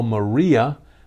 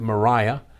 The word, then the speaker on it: Maria: I spell Maria the way we would say Maria here in the States, but they pronounced it Mariah.